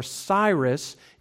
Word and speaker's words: Cyrus: The child's name in Persian was Koresh, or Cyrus is the Greek pronunciation.